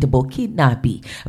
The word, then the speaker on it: Burkinabe: You know, Ibrahim Traoré, who was born on the 14th of March 1988, is a Burkinabe. That's how they say it, Burkinabe.